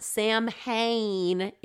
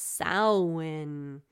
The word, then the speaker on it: Samhain: It's not Samhain, it's Samhain, as the Celts would say.